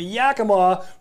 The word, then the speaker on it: Yakima: I'm going to be fishing the Yakima for spring chinook.